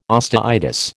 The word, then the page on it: osteitis: American English